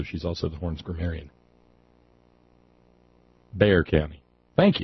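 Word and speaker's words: Bexar: Knew better than to pronounce the X. Bear County.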